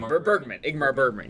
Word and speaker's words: Ingmar Bergman: Ingmar Bergman, yeah.